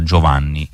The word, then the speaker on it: Giovane: Allora, buonasera cara, io volevo dirle che il nome di Giovane si pronuncia Giovanni in brasiliano, senza nessun accento, come se fosse Giovanni in italiano.